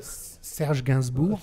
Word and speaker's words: Gainsbourg: Okay, so this is it's a Herbert remix of, and can you say the name? Because you actually say it properly. – Right. Oh, yeah. So Serge Gainsbourg.